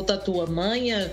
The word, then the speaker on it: Tatuamunha: Tatuamunha e ou Tatuamanha, tem pessoas que falam, enfim, tem essas pronúncias, mas é, o, essas, essa prainha, ela é muito próxima mesmo, é continuação da São José dos Milagres.